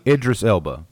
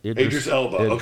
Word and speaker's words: Idris Elba: How do we pronounce Eldris Eba? – Idris Elba. – Idris Elba, he takes on a role that's basically a giant FU to Will Smith, who ruined the first movie.